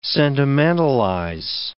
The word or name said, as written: sentimentalize